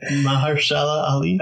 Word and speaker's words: Mahershala Ali: Mahershala Ali? – Hey, there we go.